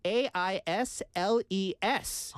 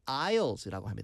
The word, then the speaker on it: aisles: 그래서 아이올 근데 스펠링은 좀 이상해요. A I S L E S 인데 아. – 발음은요. aisles라고 합니다. aisles.